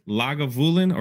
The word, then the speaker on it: Lagavulin: Did I say that right? Lagavulin or Volin 16 is my go-to.